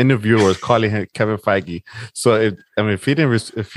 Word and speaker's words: Feige: Put the hard G in his name, man. – Feige? Feige. – All right. Gee. Soft G.